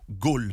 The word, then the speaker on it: ghoul: En islam, c'est le roul. Mais le roul, on va le prononcer, nous, ghoul, parce que c'est G-H-O-U-L.